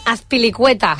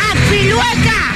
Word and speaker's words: Azpilicueta: I va cantar l'alineació de l'Atlètic pronunciant malament Azpilicueta. – És de les nostres. Belén Esteva és de les nostres. – Oblak! En defensa, Llorente! Azpilueca!